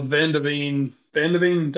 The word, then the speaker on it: Van de Ven: and also Van de Ven, did I say it right? – Yeah.